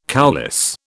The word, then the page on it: coulisse: British English